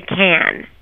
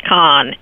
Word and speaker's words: Cannes: you got to say can. – You have to say can, just like the the Campbell soup can. Don't pronounce the s, and don't call it con. And if your grandmother starts saying that it has to be con, she's actually wrong.